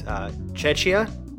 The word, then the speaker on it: Cecia: Now I'm going to pronounce this in... because I'm Italian, so I'm going to pronounce it in the Italian way, but if I'm butchering your name, I'm sorry. It's Cecia. That's C-E-C-I-A.